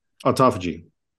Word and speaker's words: autophagy: Autophagy? – Autophagy.